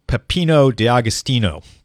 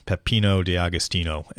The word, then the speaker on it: Peppino D'Agostino: It is Peppino D'Agostino. Peppino D'Agostino.